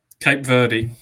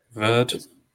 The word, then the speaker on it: Cape Verde: Not how you pronounce it, according to Ross. – Verde. – Although I actually spoke to Sasha's dad, and apparently in English it is Cape Verde, but in Portugal it's Cape Verde or Cabo Verde.